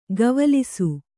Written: ಗವಲಿಸು

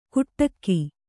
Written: ಕುಟ್ಟಕ್ಕಿ

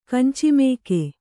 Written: ಕಂಚಿಮೇಕೆ

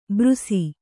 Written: ಬೃಸಿ

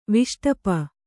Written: ವಿಷ್ಟಪ

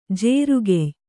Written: ಜೇರುಗೆಯ್

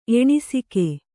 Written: ಎಣಿಸಿಕೆ

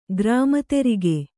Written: ಗ್ರಾಮತೆರಿಗೆ

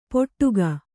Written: ಪೊಟ್ಟುಗ

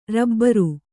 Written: ರಬ್ಬರು

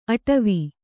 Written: ಅಟವಿ

